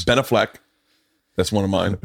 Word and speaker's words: Ben Affleck: My heart will melt if I get a text from Ben Affleck and says, hey, man, Ben Affleck.